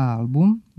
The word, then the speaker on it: album: A album, bilo da se radi o onom sa slikama ili o muzičkom albumu, kratko uzlazni akcenat na prvom slogu zadržava u svim padežima.